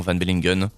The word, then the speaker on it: Van Bellingen: Voilà, donc c'est Jonathan Van Bellingen, ça passe aussi.